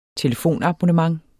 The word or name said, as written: telefonabonnementer